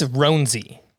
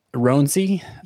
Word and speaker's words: Roansy: But Roansy Contreras, is that how you say it? – I believe it's Roansy. – Roansy? – Yes.